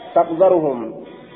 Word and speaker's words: تقذرهم: تقذرهم بفتح الزال